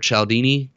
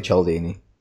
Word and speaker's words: Cialdini: And then I would also recommend Influence by, I can never say his last name, Robert Cialdini. – You said it correctly, Cialdini.